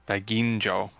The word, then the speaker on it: Daiginjo: Daiginjo. – Okay. – No soft G's in Japanese. If it's a J, it's a J.